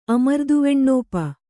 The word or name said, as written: ಅಮರ್ದುವೆಣ್ಣೋಪ